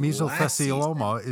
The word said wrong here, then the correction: mesothelioma: is still a problem, and you can still order a free book. I can't. I've seen that for 25 years, and I still can't pronounce the disease. Mesothelioma.